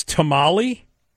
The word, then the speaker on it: tomalley: In Maine, it's tamale, and that's not like the tamale. It's spelled T-O-M-A-L-L-E-Y.